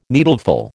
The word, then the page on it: needleful: American English